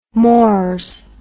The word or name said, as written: mors